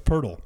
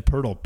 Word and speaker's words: Poeltl: Pronunciation is Jakob Poeltl. So Y-A-K-U-B. And then Poeltl, P-U-R-R-T-U-H-L. That's how you say it.